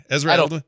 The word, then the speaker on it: Edelman: El, how am I saying that wrong? Elderman who is that guy? – Ezra Edelman. Ezra Edelman.